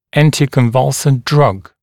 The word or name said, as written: anticonvulsant drug